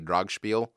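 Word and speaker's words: Dragspel: The correct pronunciation would be Dragspiel.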